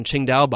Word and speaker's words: Qingdao: It's like the TS, for example, the TS that for you know that we see on Qingdao bottles mm. is within the Wade Giles system it, it is supposed to be pronounced as the CH sound, Qing, Qingdao, right?